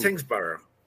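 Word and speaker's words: Tyngsborough: Well, you got the first part. It's Tingsborough. It's actually Borough. They say Borough.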